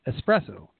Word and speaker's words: espresso: Espresso, espresso. There's no X in it.